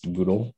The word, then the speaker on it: GDAL: There's a great tool called GDAL, GDAL. It's also pronounced Google. So you can hear both pronunciations, but it is makes data transformation very easy.